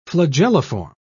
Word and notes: flagelliform: British English

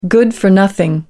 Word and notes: good-for-nothing: American English

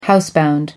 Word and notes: housebound: American English

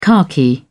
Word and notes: khaki: British English

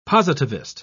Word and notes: positivist: British English

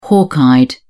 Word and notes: hawk-eyed: British English

